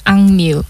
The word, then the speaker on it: AKMU: So next up, we have Fishing in the Water by Akmu. Do you want to introduce it? – Yeah. Okay, that is the name is Angmu.